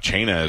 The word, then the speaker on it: Cena: Chena is one of those restaurants you may not know. It's spelled C-E-N-A. And you may look at it and be like, he gets mail for John Cena. Mm. And he may think it's something to do with Cena.